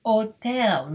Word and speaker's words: hotel: Alpaca, burro, banana, curral, fiesta, foto, horrible, hotel, remember the H is silent, hospital, no, natural, popular, rodeo, radio, taxi, tropical.